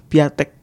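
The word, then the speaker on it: Piątek: Gue pernah baca cara bacanya tuh Piatek.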